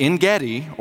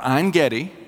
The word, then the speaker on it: Ein Gedi: And at En-Gedi, or Ein-Gedi, both pronunciations are correct, it was a spring, one of very few springs that are around the Dead Sea.